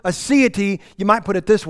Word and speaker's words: aseity: aseity. Yeah, that's right. Aseity. It's spelled A-S-E-I-T-Y. Aseity.